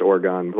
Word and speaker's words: Orgone: All right, let's pick it up with, how do you pronounce it? Is it Orgone? – I believe it is pronounced Orgone. Like you're mispronouncing Oregon, basically.